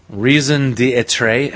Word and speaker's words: raison d'être: Their raison d'être...